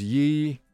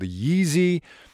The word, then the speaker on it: Ye: Uh, we all know the challenges that Kanye West, now known as Yee, right? Yee, not Ye, Yee.